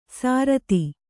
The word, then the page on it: ಸಾರತಿ: sārati